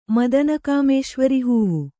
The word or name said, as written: ಮದನಕಾಮೇಶ್ವರಿಹೂವು